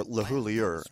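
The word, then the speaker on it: LeHoullier: I was just talking to Craig LaHoulier. Or LaHoulier, I guess he actually pronounces it.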